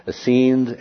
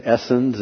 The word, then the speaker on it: Essenes: This is the explanation as to why only 1 in 17 Essenes, and you will hear it pronounced Essenes, Essenes, and with the emphasis on other syllables, right?